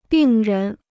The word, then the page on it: 病人: bìng rén